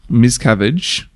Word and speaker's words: Miscavige: David Miscavige, I'm saying that right, Miscavige. – Yes.